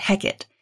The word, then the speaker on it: Hecate: The three witches summon her in the play, though in Shakespeare, they usually pronounce it Hecate.